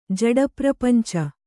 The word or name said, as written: ಜಡಪ್ರಪಂಚ